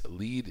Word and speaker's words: Lead: I did make a point of stopping in Lead, South Dakota, that's L-E-A-D, but pronounced Lead, and so named because it was here, around 1875, that prospectors found the first lead to a vein of gold ore.